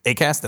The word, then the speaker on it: Acast: ACAST, did I say that right? – Yep, that's right.